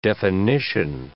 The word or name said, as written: definition